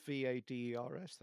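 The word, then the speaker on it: Vaidas: It's it's V. I'm probably not pronouncing right. Uh, we all say Vader's. Uh, it's V A I D A S.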